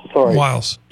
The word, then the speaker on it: Wiles: How do you pronounce her name? Susan Wilkes? – Susie Wiles. – Wiles.